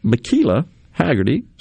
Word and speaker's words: Michela Haggerty: But joining us now in the studio from the Mississippi National Guard, a child and youth program, we've got Michela Haggerty. Said that right? – That's it.